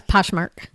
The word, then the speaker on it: Poshmark: Yeah, Poshmark.